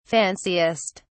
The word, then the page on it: fanciest: British English